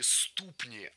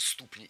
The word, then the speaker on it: ступни: И самые большие ступни относительно тела. То есть вот такие непропорционально большие ступни именно. – Ступни неверно. Правильный вариант – ступни. Слово «ступня» имеет ударение на окончании во всех формах.